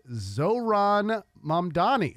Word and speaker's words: Mamdani: The list says the correct pronunciation of the mayor-elect is Zohran Mamdani.